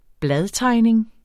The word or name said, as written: bladtegning